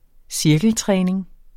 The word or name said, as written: cirkeltræning